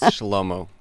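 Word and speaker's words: Schelomo: I'm going to let my guest pronounce it, because, after all, it's his language, so... – It's Shlomo.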